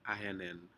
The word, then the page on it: ahen hen: Aneityum